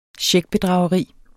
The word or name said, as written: checkbedrageri